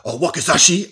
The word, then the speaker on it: Wakizashi: Wakizashi, I believe it's called, as Dolph Lundgren would explain. – Good pronunciation.